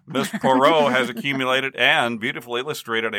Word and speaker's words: Poirot: P-O-I-R-O-T, but it's she told me it's pronounced Poirot.